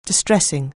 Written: distressing

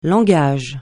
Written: langage

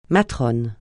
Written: matrone

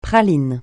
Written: praline

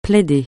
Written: plaider

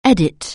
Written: edit

